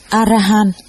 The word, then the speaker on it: Arahan: Đại A La Hán là tiếng Ấn Độ, nguyên âm đọc là Arahan,